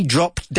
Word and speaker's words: dropped: It's regular, but we don't pronounce the ED. Yeah, he dropped dead.